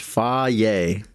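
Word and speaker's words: Fage: And that's how it's pronounced. It says it right there on the packaging. Pronounced faye.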